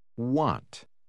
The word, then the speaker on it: want: Want. Want. – Note como a letra A tem um som distinto quando vai depois da letra W.